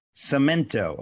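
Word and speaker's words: Samento: Is it C-I-M? – No, it's S-A-M-E-N-T-O. – Cimento.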